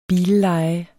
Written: billeje

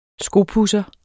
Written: skopudser

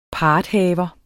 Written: parthaver

